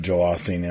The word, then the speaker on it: Joel Osteen: Yep, Joel Osteen, yeah.